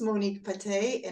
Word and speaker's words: Patè: How should I pronounce your last name? – Patè. – Patè, okay. – Yes. – Patè. Okay, I forgot to ask you, but I pronounce it right. – You pronounce it absolutely right.